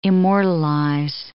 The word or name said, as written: immortalize